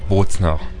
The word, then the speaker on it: Bozner: Alors, en italien, on dit Bolzanini. En allemand, on dit Bozner.